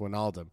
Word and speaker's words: Wijnaldum: It's pronounced Wijnaldum.